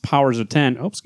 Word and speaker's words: Powers of X: Silva will illustrate Powers of X, which is pronounced Powers of 10, evoking the Roman numeral.